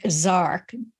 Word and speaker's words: Jark: I found this fascinating, especially because one of these entities named Zark, spelled J-A-R-K, but pronounced Zark, is a little gray.